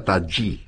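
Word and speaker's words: Tajy: Se escribe Taji con J y griega, pero me han dicho que se pronuncia Taji.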